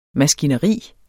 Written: maskineri

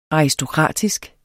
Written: aristokratisk

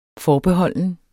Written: forbeholden

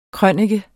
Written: krønike

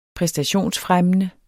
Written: præstationsfremmende

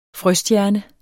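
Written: frøstjerne

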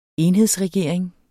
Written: enhedsregering

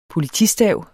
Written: POLITISTAVENES

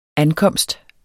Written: ankomst

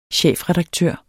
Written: chefredaktør

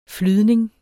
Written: flydning

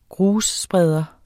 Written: grusspreder

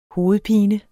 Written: hovedpinen

